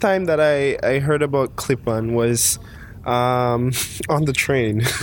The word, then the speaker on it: Klippan: Klippan.